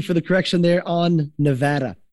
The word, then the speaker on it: Nevada: It's Las Vegas, Nevada, not Nevada the day you move to Las Vegas, they teach you that, and you will know if you're local or tourist based on how you pronounce it.